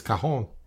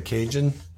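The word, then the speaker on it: cajón: I'm trying to say it as South American as I can, because I just don't want to say Cajun, because it's spelled C-A-J-O-N. If I say Cajun, I'm going to sound like a ugly American.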